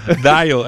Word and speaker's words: Dial: Dayo, só pro pessoal entender que se escreve Dial, né, com L no final, são as ondas sonoras.